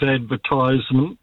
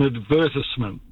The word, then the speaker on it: advertisement: And by the way, advertisement is how the word is spelt. The POMs call it advertisement, but then again, their English is not that great. It is an advertisement.